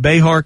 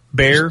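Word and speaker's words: Bexar: She was defeated in her primary runoff down in, do they pronounce it Behar County? – Bear. – It's just like a bear, yeah. – Why they put an X in that?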